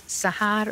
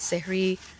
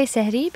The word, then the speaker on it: sehri: Some would pronounce it as sahar, suhoor. I believe you pronounce it sehri. Tell us a little bit more about that before we hear the clip. – So I would say sehri because I'm South Asian and my family speaks Urdu.